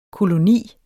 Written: koloni

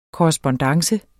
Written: korrespondance

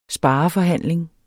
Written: SPAREFORHANDLING

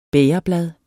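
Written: BÆGERBLAD

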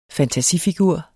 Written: fantasifigur

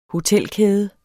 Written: hotelkæde

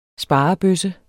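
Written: SPAREBØSSE

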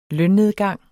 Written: lønnedgang

